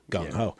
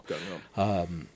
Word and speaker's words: gung ho: Mandarin, yeah, but it's pronounced the English way, which is gung ho. – Gung ho.